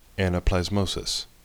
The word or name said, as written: anaplasmosis